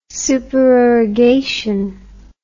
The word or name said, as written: supererogation